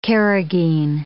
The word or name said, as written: carrageen